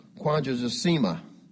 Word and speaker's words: quadragesima: The Latin word pronounced quadragesima, 40th, meaning the first Sunday, and meaning 40th days is when it was originally observed.